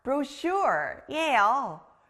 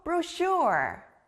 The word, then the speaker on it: brochure: 바로 책자, brochure예요. 여기 가운데 sh 소리 들렸나요? 우리가 여행을 가면 아주 다양한 정보가 들어있는 책자를 받아요. 책자는 영어로 brochure.